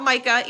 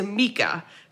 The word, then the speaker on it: Micah: It is not Micah, even though it is spelled Micah. It is Mika.